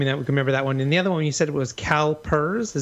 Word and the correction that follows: Caliper: Is that correct, or did I say that wrong? – Caliper. Yeah, no, it's Caliper. Caliper. Like the mechanics, Caliper.